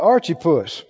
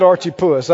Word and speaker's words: Archippus: In Arkansas and Missouri, you might pronounce it Archippus. I don't know, but that's not how they said it. So Archippus, Epaphroditus, Epi.